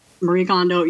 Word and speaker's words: Kondo: Marie Kondo.